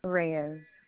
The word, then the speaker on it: Reyes: Reyes. – So Williams. – Reyes. – Spell it for me. – R-E-Y-E-S.